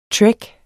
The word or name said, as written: trek